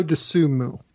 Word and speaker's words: Dosunmu: Is it Dosunmu? Is that how you pronounce it? – Io Dosunmu. – Dosunmu. That sounds better anyway.